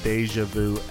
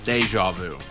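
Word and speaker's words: déjà vu: Yesterday, honestly, the biggest case of deja vu ever. – Deja vu. Not deja vu. Wow. That sounds like a fucking stripper. – Deja. – Three syllables, deja. Not two.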